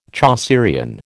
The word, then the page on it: Chaucerian: American English